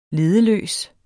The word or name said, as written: leddeløs